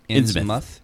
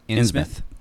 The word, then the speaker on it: Innsmouth: is it pronounced Innsmouth? – Innsmouth. – Innsmouth. All right. I kind of figured.